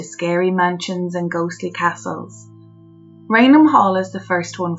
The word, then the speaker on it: Raynham: I think I'm pronouncing that right. Or A-Y-N-H-A-M, Raynham? – I would have pronounced it as Raynham as well.